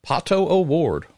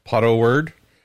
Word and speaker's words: Pato O'Ward: Um, you look at his teammate, Pato O'Ward, or as Jimmy Fallon said, what was it, Pato Word? I think that's how he pronounced it on the show.